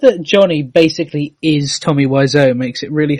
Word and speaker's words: Wiseau: It'd be Wiseau, because it's a respelling of the French word for bird.